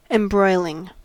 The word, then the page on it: embroiling: American English